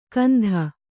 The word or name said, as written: ಕಂಧ